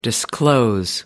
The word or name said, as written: disclose